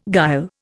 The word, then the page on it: guile: Indian